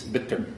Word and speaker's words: bitter: Make sure you pronounce both T's. Bitter.